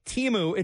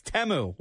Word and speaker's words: Temu: Also, it turns out it's not pronounced Timu, it's Temu, which was a major revelation.